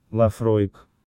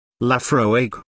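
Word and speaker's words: Laphroaig: А третье действительно чудное, Лафроек. – Лафроек.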